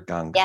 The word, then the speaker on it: Ganga: Mother Ganga.